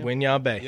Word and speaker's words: Winyah Bay: Did I say that right? – Winyah Bay. Yep. That's right.